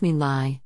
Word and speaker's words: My Lai: The My Lai massacre, pronounced My Lai, is considered the nadir in modern Army history.